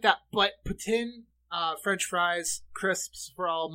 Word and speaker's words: poutine: Of course, I know a lot of people, it's pronounced poutine. If you go to Quebec, it's pronounced poutine. They're really adamant about that.